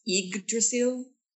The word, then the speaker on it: Yggdrasil: We talked, I think we discussed how to pronounce the name of the ship. It's Yggdrasil. – Yggdrasil, yeah.